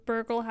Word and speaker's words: Burgel: Burgel has a hard G.